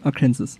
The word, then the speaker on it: Arkansas: Nein, Arkansas. Arkansas spricht man so.